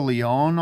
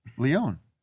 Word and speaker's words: Lyonne: We had Natasha Lyonne on last week. – Is that how she likes her name pronounced? – Lyonne.